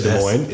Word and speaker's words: Des Moines: But here's the weird thing about when you say Des Moines is you didn't pronounce the first S, yeah. – But you pronounced the second S.